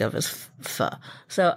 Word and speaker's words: pho: It is pho, though it's spelled pho.